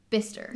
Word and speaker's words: Bicester: So there's a place in England called, like, or in Oxford, I guess, called Bicester, but it's pronounced Bister.